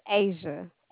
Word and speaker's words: Asia: And my name is Asia, A-S-I-A. – Oh, I'm so sorry. – You're good. – Spell it for me. I'm sorry. Spell it, please. – A-S-I-A. – Oh, my God, it's Asia like Asia.